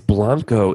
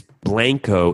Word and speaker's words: Blanco: It's Blanco in Spanish, and it's Blanco in Texas.